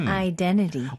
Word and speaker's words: identity: Identity. – 어, 쌤, 지금 identity라고 하지 않고 t, 첫 번째 나오는 t를 거의 무음 처리를 하셨네요.